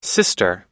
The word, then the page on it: sister: i